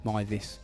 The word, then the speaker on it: Meiwes: And it's the story of Armin Meiwes or Meiwes. There's loads of different ways I've heard his name pronounced. But I'm going with Meiwes.